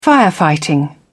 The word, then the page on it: firefighting: British English